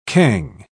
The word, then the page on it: cangue: British English